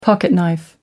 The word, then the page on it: pocketknife: American English